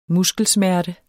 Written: muskelsmerte

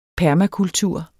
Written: permakultur

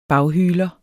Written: baghyler

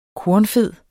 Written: kornfed

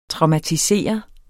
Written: traumatisere

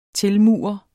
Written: tilmure

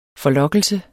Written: forlokkelse

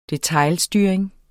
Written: detailstyring

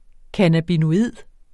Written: cannabinoid

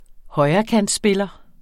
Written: højrekantspiller

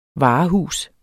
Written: varehus

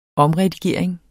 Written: omredigering

